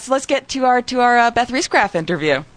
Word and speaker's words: Riesgraf: My bad, but it is Beth Riesgraf, and she is totally awesome and so beautiful.